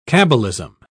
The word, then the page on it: cabbalism: British English